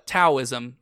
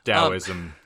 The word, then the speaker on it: Taoism: Also, Taoism. Just to because why not? – Or Taoism, depending upon your proper pronunciation.